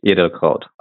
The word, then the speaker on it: Edelkraut: So my first name is Sascha, and then the last name is Edelkraut.